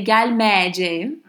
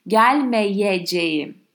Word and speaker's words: gelmeyeceğim: So you cannot say Galmeje, although some people from Anatolian hinterland, due to their dialect, might pronounce it as such. You should aim at pronouncing and writing with a Y in between two E's.